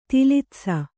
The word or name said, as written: ತಿಲಿತ್ಸ